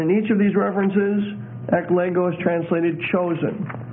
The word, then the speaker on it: eklego: The Greek for chosen is eklego, E-K-L-E-G-O. And that's a long O, eklego.